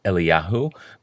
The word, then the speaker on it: Eliyahu: Rather, we almost always spell Eliyahu's name with a vav and pronounce it Eliyahu, but in five cases, his name is written without the vav and pronounced Eliyah.